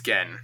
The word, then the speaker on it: Gen: There's – Gen, do you pronounce it Gen or Jen? – I believe it's Gen.